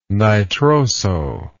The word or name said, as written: nitroso